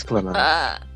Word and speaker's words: Clannad: How do you pronounce that? Clannad or Clannad? – Clannad. No, I'm kidding. It's Clannad.